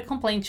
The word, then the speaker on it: complaint: As we have seen in previous classes, when we have this construction with N and T in the end, in American English, we normally don't pronounce the letter T. We have a very short ending, and then we kind of eat the letter T. It's a silent T, right? So complaint, complaint, complaint. It's a short ending, and the T is silent.